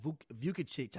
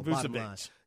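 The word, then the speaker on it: Vučević: But then you got Vukicic. How you pronounce his last name? – Yeah, go ahead. – if it has a Vic at the end of it, he can play damn basketball. Vucevic.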